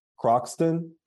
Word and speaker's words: Croxton: Croxton? – Croxton.